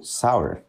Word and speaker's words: Sour: E ela tem um clipe, principalmente a melhor música do álbum dela, chamado Sour, ou Sour, eu não sei pronunciar, mas é S-O-U-R. – É sour, sour,